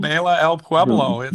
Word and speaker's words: Baila El Pueblo: No, it's called Baila El Pueblo. – Baila El Pueblo. We got to get that right.